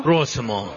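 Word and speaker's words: Prosimo (просимо): Prosimo.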